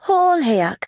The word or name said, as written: Hololheyak